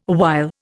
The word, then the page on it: awhile: Indian